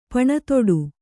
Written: ಪಣತೊಡು

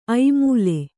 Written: ಐಮೂಲೆ

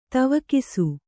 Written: ದಿವೌಕಸ

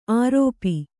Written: ಆರೋಪಿ

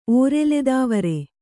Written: ಓರೆಲೆದಾವರೆ

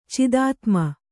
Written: ಚಿದಾತ್ಮ

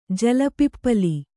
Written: ಜಲಪಿಪ್ಪಲಿ

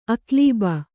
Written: ಅಕ್ಲೀಬ

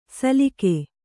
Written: ಸಲಿಗೆ

